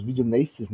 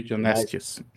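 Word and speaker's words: video nasties: Entrou para aquela famosa lista dos videonasties, né? Eu não sei se eu pronunciei correto, mas Videonestes. – videonasties, né? Que é os banidos por excesso de violência gráfica.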